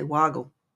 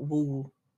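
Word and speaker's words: Wugul: Now, the way they spell it is W-U-G-U-L-O-R-A. We say Wagal, not Wool.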